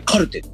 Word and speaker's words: 手記: うん、で、えー、と次がですね、えーと、あすごいなと思ったのはベベニタスの、はい、手記と書いて手記手記と書いて書いてカルカルはいカルテ